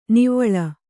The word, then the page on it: ನಿವ್ವಳ: nivvaḷa